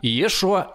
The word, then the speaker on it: Иешуа: То есть я хочу сказать, что весьма вероятно настоящий Иисус, если он был, говорил на арамейском, но имел при этом ивритское имя имя – Иешуа Мессия. Вероятно, именно такое произношение вы услышали бы, если бы попали на собрание его учеников.